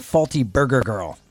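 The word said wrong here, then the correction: Flty Brgr Grl: So I think it's Filthy Burger Girl. – Ah, okay. – So they've removed all the vowels.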